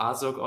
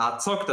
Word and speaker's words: Azog: der wird nicht Azog ausgesprochen, sondern Azog, das gehört ein Z, ein hartes Z, das muss so sein.